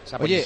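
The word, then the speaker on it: Saponjić: No, digo que la pronunciación de Saponjic podías hacer una ñ perfectamente. – Saponjic.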